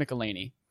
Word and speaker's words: McElhenney: McElhenney? – Mac. – Rob McElhenney.